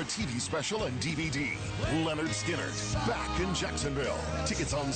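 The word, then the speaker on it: Lynyrd Skynyrd: First night, the debut album pronounced Leonard Skinner, front to back, and more.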